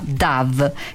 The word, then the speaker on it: Dove: Il suo contrario, come in italiano, è la colomba DAV, che si scrive esattamente come dove,